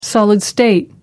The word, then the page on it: solid state: American English